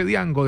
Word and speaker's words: Django: Hace días tuve una discusión con un señor que me decía que yo estaba equivocado, que no se dice Django, se dice Django, decía el señor, pero la pronunciación hace parte del idioma romaní que elimina la D y le pone todo el énfasis en la Y, de donde viene el origen del nombre de Django, que se pronunciaría exactamente así.